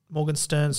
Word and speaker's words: Morgenstern: The reunion scene, which occurs after Buttercup realizes the man in black is Wesley, apparently Morgenstern, sorry, I've been saying it wrong the whole time because I read that as an I, but that's an R.